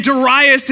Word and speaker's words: Darius: 522 BC, a new king takes the throne named Darius, spelled like the country music star, but not pronounced like the country music star, Darius. And Darius in Ezra chapter 5, he begins to search for this edict of Cyrus and he finds it again.